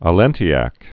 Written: Allentiacs

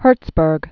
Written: Gerhard Herzberg

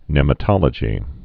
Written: nematology

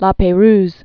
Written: La Perouse Strait

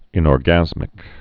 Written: inorgasmic

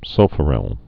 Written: sulfuryls

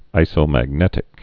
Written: isomagnetic

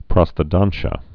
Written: prosthodontia

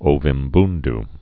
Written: Ovimbundu

